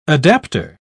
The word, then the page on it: adaptor: British English